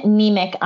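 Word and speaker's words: Niemiec: So I am Jasmine Nemec on Instagram.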